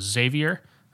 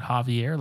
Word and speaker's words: Xavier: Yes, so we meet a friend from Chicago that we decided his name was Xavier. I was pronouncing it Javier, like a dip.